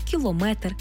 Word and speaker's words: кілометр: В іншомовних словах, які позначають міри довжин, наголос падає на метр. Тобто кілометр, сантиметр, міліметр, дециметр.